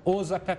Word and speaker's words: Osaka: Fica aqui meu forte abraço a toda a é, colônia japonesa que vive aqui no Brasil, porque o Japão, Tóquio em especial, Osaka, que eu aprendi que se pronuncia assim, que outros são lugares maravilhosos.